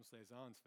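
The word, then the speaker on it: Cézanne: Cezanne.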